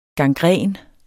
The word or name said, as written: gangræn